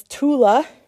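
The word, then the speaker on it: Toula: There's this girl, her name is Tula. It's like doula for pregnancy, but with the T.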